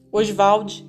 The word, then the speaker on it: Oswald: ele gostava de pronunciar o nome Oswald dessa maneira. – Oswald de Andrade.